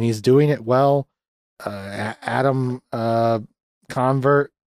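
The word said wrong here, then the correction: Allen Covert: or how the fuck you say his name? Alan Covert. Wow, I really fucked that.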